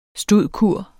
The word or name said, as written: stud.cur.